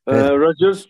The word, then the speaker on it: Roger: Roger mi, Roger mi, nasıl okunuyor tam bilemiyorum. – Roger, çünkü annesi Güney Afrikalı. Hatta ilk ana dili de İngilizcedir zaten Federer'in.